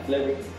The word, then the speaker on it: Flewit: Mike Mike no, no sé cómo se pronuncia, F-L-E-W-I-T. Flewitt, no, tú eres francés, no. Flewitt. Ah, Flewitt. Sí, así como tal.